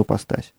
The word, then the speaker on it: ипостась: Ипостась – это греческое слово, которое мы так и произносим, ипостась. Ипостасис, как оно по-гречески, в принципе, и звучит.